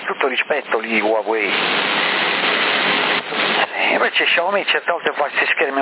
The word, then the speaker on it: Xiaomi: Ecco perché mi sono fatto un'idea su questi Xiaomi, che poi si pronuncia Xiaomi,